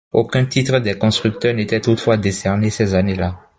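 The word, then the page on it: constructeurs: french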